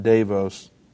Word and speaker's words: Davos: Klaus Schwab pledges the world can find salvation at Davos 2022. I don't even know if that's how you say it, but it's in Switzerland.